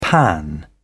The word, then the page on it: pan out: British English